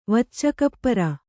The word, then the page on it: ವಚ್ಚಕಪ್ಪರ: Kannada